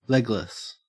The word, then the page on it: legless: Australian English